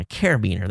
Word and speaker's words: carabiner: A carabiner, that's the correct pronunciation.